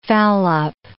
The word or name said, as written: foul-up